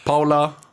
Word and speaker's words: Paul: Alltså i Skåne, om man stavar P-A-U-L så är det ju Paul.